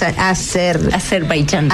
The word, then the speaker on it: Azerbaiyán: Azerbaiyán.